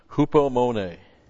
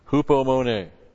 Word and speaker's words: Hupomone: Hupomone. H-U-P-O-M-O-N, long E. Hupomone.